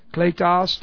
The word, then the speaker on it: kletos: K-L, long E-T-O-S, kletos, to call.